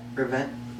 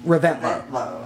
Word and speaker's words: Reventlow: How do you say that? Reventlow. – Reventlow, I believe it is.